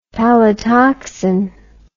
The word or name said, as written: palytoxin